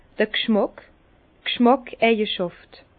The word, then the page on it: d'r G'schmàck: Bas-Rhin; Herrlisheim